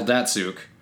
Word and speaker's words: Datsyuk: But if you saw his last name, you would have no idea how to say it. – Mm. – It's Datsyuk. So you think Datsyuk? – But it's Datsyuk.